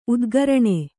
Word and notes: ಉದ್ಗರಣೆ: udgaraṇe; Kannada

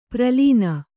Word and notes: ಪ್ರಲೀನ: pralīna

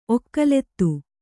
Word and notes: ಒಕ್ಕಲಿತಿ: Kannada

